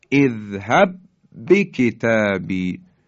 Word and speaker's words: اذهب بکتابی: در صفحه 124 جزوه توجه بفرمایید، کلماتی از قبیل اذهب بکتابی، حرف ساکن، حرف با اذهب، حرف متحرک بعدی بکتابی هست، اینا در هم ادغام میشن و در حقیقت محکم و با شدت ادا میشن. ما یک با رو داریم تلفظ میکنیم، حرف اولی که ساکنه یعنی مدغم حذف میشه. دقت بفرمایید، اذهب بکتابی، اذهب بکتابی، دیگه حرف اول رو تلفظ نمی کنیم، میگیم اذهب بکتابی.